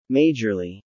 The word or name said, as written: majorly